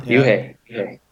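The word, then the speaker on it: Ryuhei: Ryohei Kitamura. – Very close, yeah. Uh-huh. Yuhei. Yeah.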